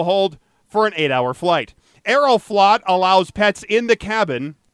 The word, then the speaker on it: Aeroflot: And he was distraught when the Russian airline Aeroflot, I'm sure I'm not pronouncing that in Russian properly. – No, I think he nailed it.